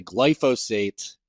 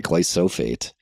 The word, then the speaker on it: glyphosate: I've been pronouncing glyphosate wrong for my entire life. There's some like middle of the word spoonism. I've been calling it glysophate for the many many years.